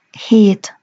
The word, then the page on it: het: Hungarian